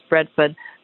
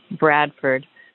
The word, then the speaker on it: Bradford: I see a native Bradford person saying the pronunciation is Bradford, which is a wonderful Vermont dialect, but most of us, I think, say Bradford with the emphasis on Brad and Ferd, like F-E-R-D.